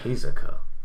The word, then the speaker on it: Hisoka: Hisoka.